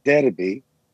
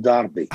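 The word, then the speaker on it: Derby: طيب يعني إذا تيجي تحكي لحدا هون ديربي مش راح يفهم عليك، ما هي يكون داربي.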